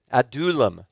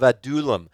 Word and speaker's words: Adullam: Adullam, it would be pronounced, the cave of Adullam.